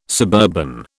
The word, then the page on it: suburban: British English